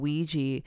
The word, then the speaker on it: Weegee: So when you had first mentioned Ouija, I was thinking the name Ouija was spelled like Ouija board, but it's Weegee.